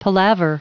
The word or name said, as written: palaver